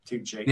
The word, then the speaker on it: Tucci: How do you say his surname? – Tucci.